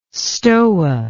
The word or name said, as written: stoa